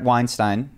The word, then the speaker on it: Weinstein: So one of the other things that I've been thinking about is Brett Weinstein, we got it correct on the last podcast, that's how you say his name. – Like Einstein with a W.